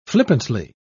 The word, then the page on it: flippantly: British English